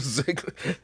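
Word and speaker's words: Zegler: Ziegler. – Rachel Ziegler.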